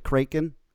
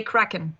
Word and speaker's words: Kraken: First of all, your newest video premiere for, do you pronounce it Kraken? – Almost, we say Kraken.